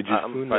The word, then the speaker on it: Jephunneh: Two of the men had explored the land, Joshua son of Nun and Caleb son of uh, Je- how do you pronounce that? Je- Jephunneh? J- uh, Yeah, Jafune. I, I believe it's uh yeah actually uh Jafune.